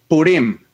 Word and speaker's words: Purim: Entonces, mis hermanos, el día de hoy vamos a estar compartiéndoles acerca de la historia de Esther y la fiesta de Purim. Lo estoy pronunciando bien, tal vez tú me puedas ayudar ahí. – Sí, eso es, está muy bien, la fiesta de Purim.